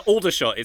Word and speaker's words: Aldershot: Aldershot.